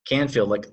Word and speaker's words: Canfield: And then Process Zip is spelled P-R-O-C-E-S-S and Z-I-P. – And your last name, Canfield? – Canfield, like a soda can plus a field. Imagine a field of cans. C-A-N-F-I-E-L-D.